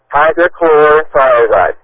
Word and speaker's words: hydrochlorothiazide: Is it pronounced hydrochloric? Thiazide is the medication you're on? – Hydrochlorothiazide. – Okay, hydrochlorothiazide, okay.